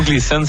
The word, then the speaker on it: Gleason: Comment ça se prononce comme ça? – Ouais, c'est le mont Gleason, c'est la petite station de ski à côté de chez nous.